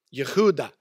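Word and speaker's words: Yehuda (יהודה): In Hebrew, you pronounce it Yehuda, all right?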